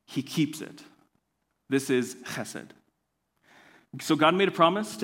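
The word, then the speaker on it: chesed (חסד): This Hebrew word, it's pronounced chesed,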